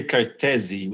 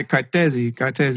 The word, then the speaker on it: Cartesi: Actually say Cartesi with an open E. – Gotcha. Cartesi.